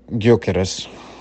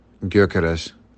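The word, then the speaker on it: Gyökeres: And so the way that you would pronounce it is, so the G that is there with the Y next to it would be like a gya sound. Then the O has the two dots above it, or the umlaut. And in Spanish it's called a diéresis. But it would be more like an sound. So, and then the K is pretty solid, it's just K, and then R, so it would be Gyorkes is how you would pronounce it.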